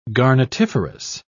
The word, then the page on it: garnetiferous: British English